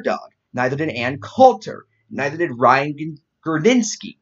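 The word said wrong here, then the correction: Girdusky: Gerdusky? Yeah, Gerdusky. That's how it's pronounced.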